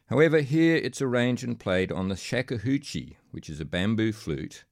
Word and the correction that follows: shakuhachi: by the American-born, Australia-based Riley Lee, who, in 1980, became the first non-Japanese person to become Daishihan, or Grandmaster of the Shakuhachi. Sorry, I'm mispronouncing that. Shakuhachi. Shakuhachi.